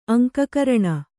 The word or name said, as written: ಅಂಕಕರಣ